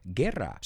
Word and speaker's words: guerra: By the way, the word wore, llevar puesto en pasado, sounds exactly like the word war, guerra. So you could remember this sentence, they wore helmets in the war. Llevaban cascos en la guerra. It's a good way to remember the pronunciation.